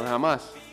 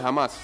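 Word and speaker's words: Hamás: Jamás. ¿Cómo se dice el grupo? Grupo Jamás. ¿no? – El hijo de Jamás.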